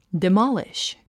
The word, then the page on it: demolish: dimάliʃ; ディマリシュ